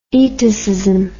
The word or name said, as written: itacism